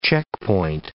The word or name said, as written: checkpoint